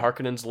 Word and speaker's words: Harkonnen: The Harkonnens left, and now House Atreides is coming, like, which if we are to take this film as gospel, then Harkonnen is the correct pronunciation, and they did me right on pretty much everything else, so I concede Harkonnen.